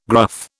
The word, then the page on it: gruff: British English